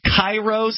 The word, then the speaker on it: Cairo: What was the we had a station in Cairo, C-A-I-R-O, Illinois, except they pronounced it Cairo.